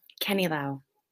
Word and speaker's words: Canilao: Canilao.